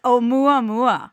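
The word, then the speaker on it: 'Oumuamua: And I always freak out when I have to pronounce it, but it's Oumuamua.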